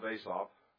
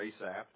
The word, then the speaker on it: Asaph: It is a psalm of Asaph, or Asaph, depending on how you want to pronounce that.